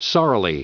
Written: sorrily

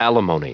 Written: alimony

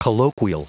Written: colloquial

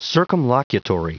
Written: circumlocutory

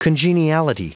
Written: congeniality

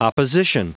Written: opposition

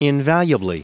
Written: invaluably

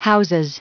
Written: houses

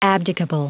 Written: abdicable

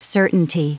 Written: certainty

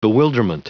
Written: bewilderment

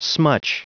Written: smutch

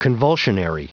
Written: convulsionary

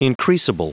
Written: increasable

